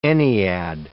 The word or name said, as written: ennead